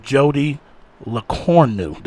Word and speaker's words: Lecornu: So tonight, we have the sister of Jodi Lecornu. Did I say that right? – Yes.